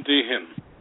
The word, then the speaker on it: Deehan: Our next guest is Tim Deehan.